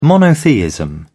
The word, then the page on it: monotheism: British English